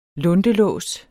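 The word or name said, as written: luntelås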